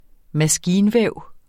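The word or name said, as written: MASKINVÆV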